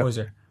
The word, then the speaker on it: Poyser: Is that my pronounce his last name right? – Mm, yeah, James Poyser. Yeah. – Poyser.